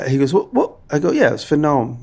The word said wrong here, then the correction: Noom: He goes, I don't think that's how you say it. I think it's called Noom. I was like, don't tell me that. He said, because my mom does it, and it's called Noom. It's not Gnome. Because Gnome is a garden gnome.